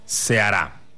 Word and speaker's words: Ceará: Ceará.